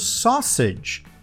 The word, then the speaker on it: sausage: Só sede. Soa como sausage. Sausage.